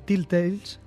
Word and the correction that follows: Telltale: Sí, porque al principio lo has dicho bien y ahora has cagado. – Telltale.